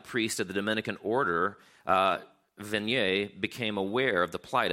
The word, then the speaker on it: Vanier: There's a man named Jean Vanier, although he's French, so I'm sure it's pronounced Vanier.